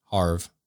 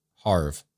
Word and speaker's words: Havre: This offers veterans enrolled in VA healthcare living in Havre, Havre, like it's it's spelled like Favre but with an H, Havre, the opportunity to receive the vaccine.